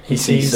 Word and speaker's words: HESES: I mean, the figures from the HESES, is that how you pronounce it? – Yep, HESES.